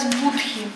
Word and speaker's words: Будди: Будди. Ну, раньше называлась Будхи, сейчас Будди.